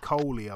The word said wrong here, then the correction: Cully: I was wondering who that was. Gary Cully. Yeah. I know who you're talking about. – Oh, I'm sorry. Yeah. I pronounce it Cully.